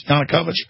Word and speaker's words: Yanukovych: How do you pronounce that? Yanukovych? Am I pronouncing that right, guys? Yanukovych? I am pronouncing it right.